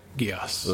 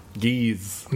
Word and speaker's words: Geass: Yeah, Geass. – Ugh. – It's like the D&D spell. – Geese. – No. It's not geese.